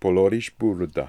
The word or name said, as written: Polorishboorda